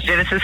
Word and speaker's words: Genesis: So that's Genesis Harris at Facebook.